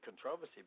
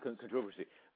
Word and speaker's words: controversy: By the way, you know, there's just a little differences in pronunciation. In America, we would say controversy. – Ah, controversy. – Sorry. I'm learning. – Yeah, like in, it's the British English versus North American.